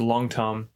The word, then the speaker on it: Long Tom: It's pronounced Long. It's the indigenous name is Long Tom, like the word long and tum, like T-U-M.